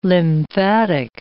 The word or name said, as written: lymphatic